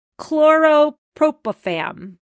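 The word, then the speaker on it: chlorpropham: Chlorpropafam. – You got it right. Got it right. – All right, talk to me about chlorpropafam.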